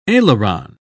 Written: aileron

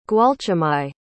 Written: gwalchmai

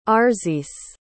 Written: Arzice

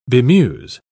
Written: bemuse

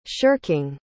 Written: shirking

